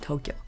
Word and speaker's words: Tokyo: A common mispronunciation of the city is that you sound the O's short. But that's actually not the case. You want to elongate every single O that is in the word Tokyo.